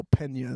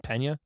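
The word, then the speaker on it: Peña: Pena? – Yeah, Pena. – Is that how you people pronounce it? – Yes, it's not Pena. – No, it's P-E-N with the squiggly line A.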